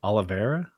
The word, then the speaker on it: Oliveira: He recently lost to, I struggle with this pronunciation, Oliveira? – That works.